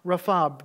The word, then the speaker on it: Rapha: This is the name Jehovah Rapha, or Rofi, but I think it's best pronounced Rapha, Jehovah Rapha.